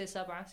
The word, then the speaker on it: sab'a: Fawad, how do you say sab'a? Say this. The letter ba has to be pronounced with qalqala sound.